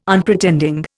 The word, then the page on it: Unpretending: Indian